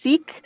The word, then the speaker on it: Sikh: There's a woman who is sick. Or it's sometimes pronounced Sikh religion.